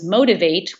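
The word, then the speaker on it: Motiv8: And the title is Motivate, which is spelled M O T I V, and then the number eight so it's pronounced motivate, and the tagline is eight ways to rock your own world.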